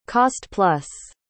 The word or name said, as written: cost-plus